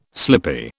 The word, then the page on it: slippy: British English